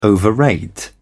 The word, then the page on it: overrate: British English